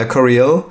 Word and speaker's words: Coryell: Dan Coriel, spricht man ihn so genau. aus? Genau, ja, würde ich sagen. Ja, Coriel.